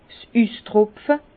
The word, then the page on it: 's Üstropfe: Bas-Rhin; Herrlisheim